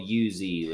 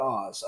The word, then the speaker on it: Uz: That's spelled U-Z, ladies and gentlemen. – Not Oz, Uz.